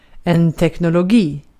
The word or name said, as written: teknologi